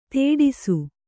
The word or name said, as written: ತೇಡಿಸು